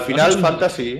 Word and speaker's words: Final Fantasy: Sin dudas, digo, ¿cómo se pronuncia al final esto? ¿Al Final Fantasy? ¿Al Final Fantasy? – Al Final Fantasy. – Lo has hecho bien.